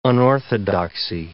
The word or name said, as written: unorthodoxy